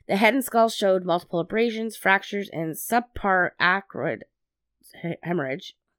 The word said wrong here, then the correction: subarachnoid: Did I say that right? – I have to see what you're writing. Well, sub subarachnoid. – Yeah, subarachnoid.